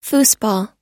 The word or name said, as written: foosball